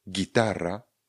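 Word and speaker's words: guitarra: Respektive my vynecháme výslovnost toho Učka. Ono je v podstatě němé pro nás tu chvíli. Miguel je příklad jeden, Gitara, gitara je příklad druhý. Gitara píšeme G, U a I, pak je tara. Ano, T, A, R, R, A. Dvě R se ještě dostane výslovnost. Gitara. G a U a I vyslovíme jako G.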